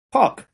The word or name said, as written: Puck